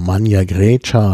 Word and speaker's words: Magna Graecia: getragen, denn es gab ja schon früh griechische Kolonien, die sogenannte Magna Graecia oder Magna Grecia oder wie man das dann ausspricht. Magna Grecia ist die moderne lateinische Aussprache, ähm, die ja eben dann die griechische Tradition nach Süditalien bringt mhm.